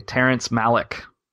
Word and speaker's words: Terrence Malick: Terrence Malick.